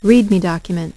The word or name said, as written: Read Me document